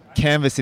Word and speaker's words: Canva: And we asked one of our engineers who happened to be French, and we're like, what's the word canvas in French? And he's like, it is Canva. – Ah. – Unbeknownst to us, it's spelt exactly the same as canvas. They don't pronounce the S though.